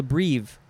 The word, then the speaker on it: breve: Now we have breve, B-R-E-V-E, could also be pronounced brev.